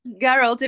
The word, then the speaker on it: Geralt: Geralt, I mean, yes.